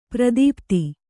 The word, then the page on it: ಪ್ರದೀಪ್ತಿ: pradīpti